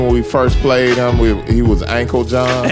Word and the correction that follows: Uncle John: Ankle. – No, his name is Uncle John.